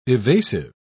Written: evasive

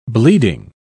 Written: bleeding

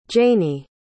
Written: Janey

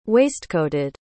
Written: waistcoated

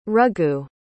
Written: raghu

